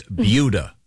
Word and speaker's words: Buda: No, it's pronounced like beauty. So it's Buda. B-U-D-A.